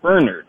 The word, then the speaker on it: Bernard: My actual name is spelled Bernard, but I pronounce it Bernard.